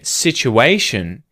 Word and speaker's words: situation: And then situation is a noun. And it ends with a suffix, shun, right? Or ation. And when that happens, the syllable before shun is the one that gets stressed. So, you're going to hear situation. It's on the A sound.